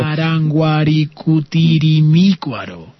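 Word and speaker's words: Parangaricutirimícuaro: Pero Paranguaricutirimícuaro. Vaya. – Exacto, porque me faltaba ahí la tilde.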